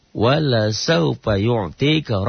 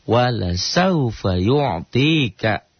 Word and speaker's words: wala saufa yu'tika: Nah, dibaca, bila dibacalahnya panjang nih, kan tadi wala saufa yu'tika. Gitu. Tiba-tiba kita membacanya wala saufa yu'tika, lanya jadi panjang. Nah, ini artinya akan beda. Dengan lam dibaca panjang, ya, maka artinya menjadi bertolak belakang.